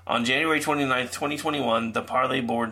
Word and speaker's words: Parler: So, in case you hadn't heard, the CEO of Parler, or actually, as it's supposed to be pronounced, Parlay, says he has been fired by the board.